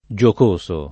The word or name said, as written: giocoso